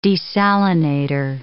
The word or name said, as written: desalinator